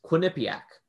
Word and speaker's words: Quinnipiac: correctly pronounces Quinnipiac, comma, world rejoices. Back in one of his speeches, the president of the United States, Donald Trump, pronounced Quinnipiac as Quinnipiac.